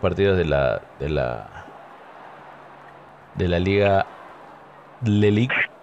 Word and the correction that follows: Ligue: ¿cómo se dice? La Ligue. la Ligue.